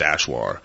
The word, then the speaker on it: Achuar: Yeah, and it was spelled A-C-H-U-A-R, pronounced Ashwar,